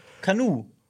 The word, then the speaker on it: Kanu: Ey, wie spricht man dieses Wort aus? Ist es Kanu oder Kanu? – Aber das sagen die im Film auch mal. Ja, unterscheidet. Kanu. Aber deswegen, das reimt sich ja auf Schuh.